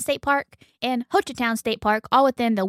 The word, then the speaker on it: Hochatown: Hochatown.